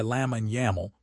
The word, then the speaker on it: Lamine Yamal: A new generation of stars has arrived, led by Lamine Yamal, pronounced L-A-H mean Y-M-A-H-L.